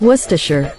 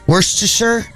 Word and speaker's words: Worcestershire: I never pronounced that right. Worcestershire. I used to call it Worcestershire because that's what it looked.